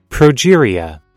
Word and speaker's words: progeria: The spike protein, in essence, ends like a progeria drug. – Progeria. – Progeria drug. I said it right.